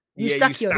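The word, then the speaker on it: Eustáquio: That's not how you say his name. It's I swear it's because I know exactly who you're talking about as well. Eustachio, that's what it is.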